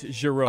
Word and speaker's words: Jarreau: I mean, it's not Jarreau. You're doing it with a hard J. It's Jarreau.